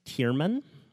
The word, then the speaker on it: Thiermann: T-H-I-E-R-M-A-N-N. Pronounced Tierman.